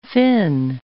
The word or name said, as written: Finn